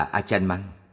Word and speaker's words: Acharn Mun: Thuở thiếu thời, ngài Pra Achan Mun, đọc là Achan Mang, là vị thiền sư lỗi lạc nổi danh nhất trong thời của ngài.